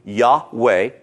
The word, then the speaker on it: Yahweh: or the phonetic pronunciation of this word, is Yahweh, two syllables, and often shortened to Yah.